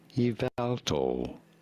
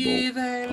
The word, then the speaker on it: Yveltal: Yveltal. You do want to stress on the second syllable. – Yveltal.